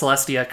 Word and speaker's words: Celestia: You did, in fact, Edgar, pronounce the name of Princess Celestia correctly.